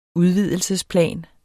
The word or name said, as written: udvidelsesplan